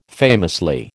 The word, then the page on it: famously: American English